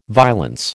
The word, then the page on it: violence: American English